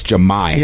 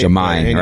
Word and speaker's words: Jahmy: The whole time I'm saying Jemiah. – And he corrected it. – And we said it right. We're like, oh, it's Jemiah. – Yeah, we had it right. – It's Jemiah, right?